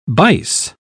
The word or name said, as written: bice